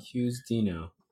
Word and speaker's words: Chiusdino: Chiusdino. – Yeah. It's a good pronunciation.